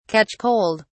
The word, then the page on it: catch cold: British English